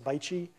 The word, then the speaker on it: BAIC: You'll see in one of these slides that Beijing Auto, or B, it's the acronym is BAIC, or it's pronounced Baichi.